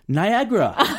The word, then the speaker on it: 나이아가라: 만약에 어, 그렇게 되면 죽게 되면 이 협상이 결렬되면 So might your dream of harnessing power from 나이아가라의 본토 발음은? – 나이아그라.